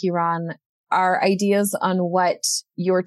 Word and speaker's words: Iran: Is Iran, like E and then run.